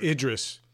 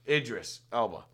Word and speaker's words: Idris Elba: And they used Aldris Elba. Is that how you say his name? – I love that actor, dude. – Idris. – Idris Elba.